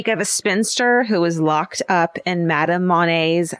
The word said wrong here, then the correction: Monnier: house. Monnier's? M-O-N-N-I-E-R-S. – Monnier. Yeah, I mean, yeah.